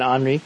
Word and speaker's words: Henri: emergency for New York City because Hurricane Henri, I think is how you pronounce it. To me, it looks like Henry, but Hurricane Henri.